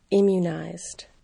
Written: immunized

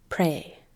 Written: pray

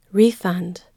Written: refund

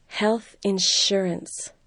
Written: health insurance

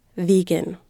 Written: vegan

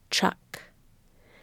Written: truck

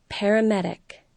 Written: paramedic

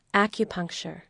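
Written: acupuncture

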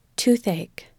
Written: toothache

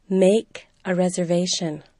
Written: make a reservation

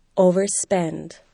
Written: overspend

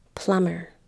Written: plumber

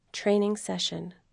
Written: training session

